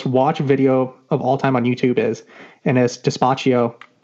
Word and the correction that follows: Despacito: by Louis, I can't even pronounce that name. Louis Fonsi. – Oh, that's Despacito.